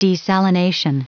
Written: desalination

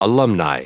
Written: alumni

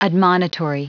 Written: admonitory

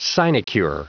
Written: sinecure

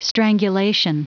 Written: strangulation

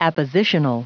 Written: appositional

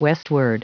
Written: westward

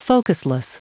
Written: focusless

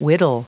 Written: whittle

